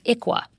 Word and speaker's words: ICWA: It's spelled ICWA and pronounced as the acronym ICWA.